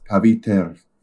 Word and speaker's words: Pavitr: Um, uh, but I, I looked up a pronunciation thing, and uh, it's pronounced Pavitr.